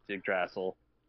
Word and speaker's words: Yggdrasil: No, Major, you obviously watch Screen Rant because he pronounces it incorrectly as well on Screen Rant. I love Ryan Airy, but it's Yggdrasil.